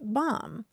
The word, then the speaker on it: BOM: The acronym for it is BOM, pronounced bomb.